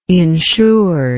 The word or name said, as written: insured